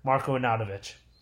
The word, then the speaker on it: Marko Arnautović: Marko Arnautović. – Hey, you said his name right. Good job.